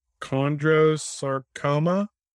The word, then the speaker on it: chondrosarcoma: So it's chondrosarcoma? – That's correct.